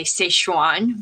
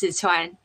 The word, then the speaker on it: Sichuan: and he is from the province of Sichuan, or some of you like to say Sichuan, but it's pronounced Sichuan.